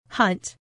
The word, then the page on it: hunt: female